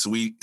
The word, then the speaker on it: Saweetie: I hate saying Saweetie, but her name's Sweetie. His name is Sweetie, like, but it's spelled Saweetie, but Saweetie, like the cereal.